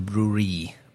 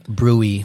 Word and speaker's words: Brouille: So what do we know about Côte de Brouille? How do you pronounce that? – Brouille. – Brouille, right? Yeah. Brouille. – So it's Brouille.